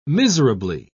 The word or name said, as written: miserably